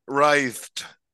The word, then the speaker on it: writhe: Writhed. – I thought you were talking about riding. – No, writhe. It's like a scythe, but with a writhe.